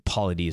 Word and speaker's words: Paulides: As noted at the beginning of the show, coming up in about 3 weeks, David Paulides, I believe Paulides is how you pronounce his last name, Paulides. It's P-A-U-L-I-D-E-S.